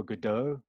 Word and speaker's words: Godot: But you know, like a play like Waiting for Godot, you know, am I saying it correctly? – Uh-huh.